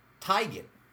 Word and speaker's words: Teigen: Apparently, for years, we've been saying it wrong. She claims yesterday, and her mother confirms, it's actually... Teigen.